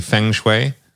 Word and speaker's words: feng shui: And am I even saying it correctly? Feng shui? – Feng shui. That's how I was taught.